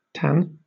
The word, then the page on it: tan: British English